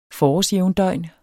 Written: forårsjævndøgn

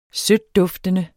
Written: sødtduftende

